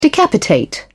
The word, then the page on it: decapitate: British English